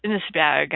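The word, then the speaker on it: Inis Beag: So, number eight is crazy little place off the coast of Ireland called Innisbjerg, and I think I'm pronouncing it completely wrong. So, my Irish friends out there, if you know how to do any Gaelic and you can say this place, please say it correctly for me. It's I N I S B E A G, two little things.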